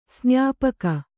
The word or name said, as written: ಸ್ನಾಪಕ